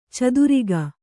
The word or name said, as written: ಛತ್ರಕ